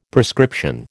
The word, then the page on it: prescription: American English